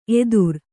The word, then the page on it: ಎತ್ತರ: Kannada